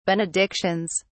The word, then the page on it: benedictions: British English